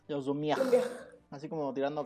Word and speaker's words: Lumière: no, sé cómo se pronuncia en francés, aquí el gordito que eh, ha estudiado francés me puede decir, los hermanos Lumière, eh, hace... – Sí, así se pronuncia.